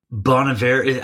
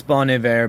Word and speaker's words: Bon Iver: Because I saw you posted she's guesting with a Bon Iver and Bon Iver. How do we say it? I don't know. – I think it's Bon Iver because I think it means it's good winter in French.